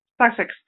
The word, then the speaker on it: Sussex: Sussex, ¿cómo se dice la localidad esa? – Sussex.